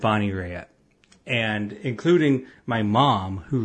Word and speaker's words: Raitt: I will concede that her name is Bonnie Raitt, R-A-I-T-T, mm-hmm. and there's no, it's a single syllable.